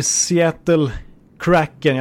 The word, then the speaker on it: Seattle Kraken: Det blir Seattle Kraken. Jag tar det på det amerikanska uttalet här. Det på svenska låter lite annorlunda som du har konstaterat direkt i nyhetsartikeln, såg jag.